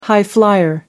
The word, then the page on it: high flyer: American English